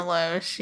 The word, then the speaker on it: Loesch: Dana Loesch? – Yeah. – Is that how you say her name? – Dana Loesch, yeah.